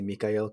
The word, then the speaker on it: Mikael: Whereas recently I've been furthering my relationship and devotion to Saint Michael the Archangel, or I like to say Mikael, kind of like closer to the Hebrew pronunciation.